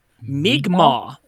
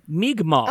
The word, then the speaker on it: Mi'kmaq: It's Mi'kmaq. There you go. Mi'kmaq. – Ah, Mi'kmaq. – Interesting. I would not have assumed from the apostrophe K-M-A-Q. That's just my white pronunciation. Mi'kmaq.